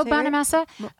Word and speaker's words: Bonamassa: Bonamassa? – Yeah. That's how Joe Bonamassa.